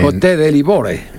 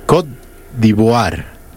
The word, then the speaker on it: Côte d'Ivoire: A ver, Côte de Livore. – Côte d'Ivoire.